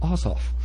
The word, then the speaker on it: Asaf: But it's pronounced Asaf. Asaf.